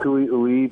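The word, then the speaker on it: cui-ui: The cutthroat are native species, the kiwi, pronounced kiwi-ui by the tribe.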